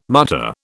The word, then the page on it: mutter: British English